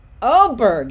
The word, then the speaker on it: Åberg: But speaking of a new golfer on the horizon, Ludvig Åberg, is that how you say it? Åberg? Åberg? I should know this. I'm part Swedish. – You nailed it right the first time.